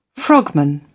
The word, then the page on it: frogman: British English